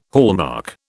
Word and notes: hallmark: British English

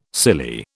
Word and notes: silly: British English

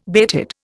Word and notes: bated: Indian

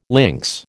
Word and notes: lynx: American English

